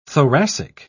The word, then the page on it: thoracic: British English